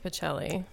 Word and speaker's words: Bocelli: And Andrea Bocelli, I believe I said that right. – I think it's Bocelli. – Bocelli, okay, sorry.